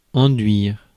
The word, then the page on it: enduire: France; /ɑ̃.dɥiʁ/